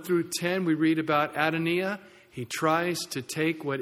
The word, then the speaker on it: Adonijah: I know it says Adonijah, but I went online and I was able to click on some little thing where this Hebrew guy said Adonijah. So that's how they think it's pronounced. So that's what we'll call him.